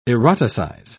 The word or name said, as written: eroticize